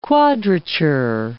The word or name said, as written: quadrature